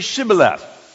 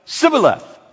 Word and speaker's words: Shibboleth: When he said, "No," they said to him, "Well, then say Shibboleth. And if you are from Ephraim, you can't pronounce it that way. You didn't learn the S-H sound. Sh. And it comes out Sibboleth."